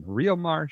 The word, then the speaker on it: Riomar: R-I-O-M-A-R. My usual spelling. Riomar Shoes. Am I pronouncing that correctly? – That you're spot on, big guy.